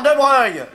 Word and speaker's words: de Broglie: Ça s'écrit De Broglie. C'est des noms de faucheton, ça s'écrit pas comme ça se prononce.